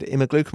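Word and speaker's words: Immerglück: And David Imagluk, am I pronouncing that right? – You are.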